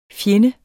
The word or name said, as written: FJENDES